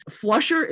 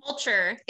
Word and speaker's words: Fulcher: Flusher, am I pronouncing Russ Flusher's name correctly? – Fulcher.